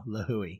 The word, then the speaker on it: Lihue: Every vowel you pronounce. There you go. Lihue.